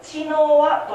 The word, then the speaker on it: 茅の輪: 茅の輪と書いて知の輪と読むそうなんですで知の輪くぐりという行事なんですね聞いたことある方おるかもしれないですけどねそう知の輪